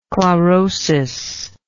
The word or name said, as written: chlorotically